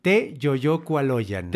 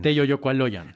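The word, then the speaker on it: Teyoyocualoyan: La sílaba tónica está en la penúltima sílaba en mexicas, en mexicano, en náhuatl. Te yoyocualoyan. Te, te yo, a ver otra vez. Te yoyocualoyan. Te yoyocualoyan.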